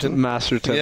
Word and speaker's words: Masterton: you feel honored being named as how do you say? – Masterton, yeah.